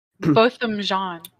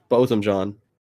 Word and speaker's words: Botham Jean: Botham John. – Botham John.